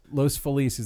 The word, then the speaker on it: Los Feliz: Los Feliz is the correct way to pronounce that city. And it's not like I'm going Los Angeles. Los Feliz is how you pronounce that word.